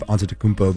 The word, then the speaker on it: Antetokounmpo: Corey, I'm really impressed on your pronunciation of Antetokounmpo, by the way.